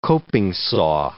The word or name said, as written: coping saw